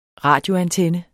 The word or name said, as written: radioantenne